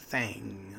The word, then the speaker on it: Thang: T-H-A-N-G, Fang. That's how they say it in Alabama.